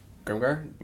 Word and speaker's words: Grimgar: Grimgar? G-R-I-M-G-A-R. – Oh, okay. Just as it sounds.